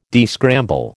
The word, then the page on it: descramble: American English